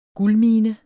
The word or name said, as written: guldmine